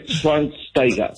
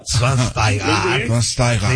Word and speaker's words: Schweinsteiger: Después de 37 años de vida, ya sé cómo se pronuncia. Bastian Schweinsteiger. – Schweinsteiger. Sí, muy bien.